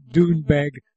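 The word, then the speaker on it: Doonbeg: It's D-O-O-N-B-E-G. I would call it Dunbeg is pronouncing it properly.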